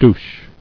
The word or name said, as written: Douche